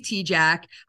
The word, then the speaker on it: Tejcek: And we are starting out strong uh, with an awesome guest, First Lieutenant Riley Tejcek, a member of the U.S.